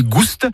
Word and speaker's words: Gouste: Ligouli Gouste, avec prononciation du S originel que l'on retrouve dans le provençal Goustus, goûteux, et qui a été remplacé depuis par l'accent circonflexe dans notre orthographe moderne.